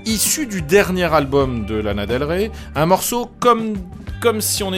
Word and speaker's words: Lana Del Rey: Et justement, il a travaillé, et il travaille encore, c'est le producteur de Lana Del Rey. Je l'ai prononcé correctement, n'est-ce pas Sylvain? Salut. – Tu n'as pas fourché, c'est bien.